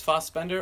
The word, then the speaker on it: Fassbender: According to a couple people who have said it, it's Fassbender, or as I used to call it, Fassbender.